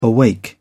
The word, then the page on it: awake: British English